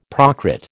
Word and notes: Prakrit: American English

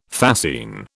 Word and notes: fascine: British English

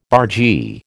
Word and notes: bargee: American English

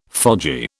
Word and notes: fogy: British English

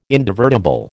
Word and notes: indivertible: American English

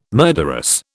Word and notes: murderous: British English

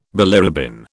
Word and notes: bilirubin: British English

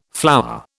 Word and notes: flour: British English

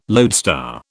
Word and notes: loadstar: British English

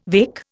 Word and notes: weka: Indian

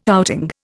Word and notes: outing: Indian

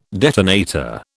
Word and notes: detonator: British English

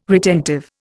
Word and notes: retentive: Indian

